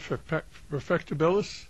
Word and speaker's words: Perfectibilist: So that's, and how do you pronounce the title again, Perfectibilist? – Yep.